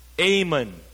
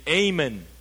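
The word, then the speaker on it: Amen (אמן): It is pronounced Amen. Say with me, Amen.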